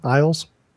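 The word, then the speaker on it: Illyes: Is that how you pronounce his name? Iles? – That's what I call. Yeah. – Yeah, that's how I pronounce it.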